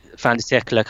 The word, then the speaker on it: fin de siècle: fin de siècle kind of um,